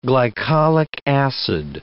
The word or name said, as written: glycollic acid